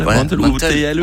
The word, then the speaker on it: Mantel: Moi, je mettrais M-A-N-T-E-L, je dirais. – Mantel. En fait, c'est Mantel, mais il faut manger la dernière syllabe.